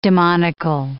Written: demonical

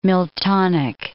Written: Miltonic

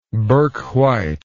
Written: bourke white

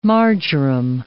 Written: marjoram

